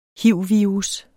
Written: hiv-virus

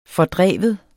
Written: fordrive

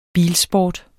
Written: bilsport

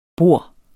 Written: borde

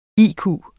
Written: IQ

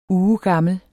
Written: UGEGAMLE

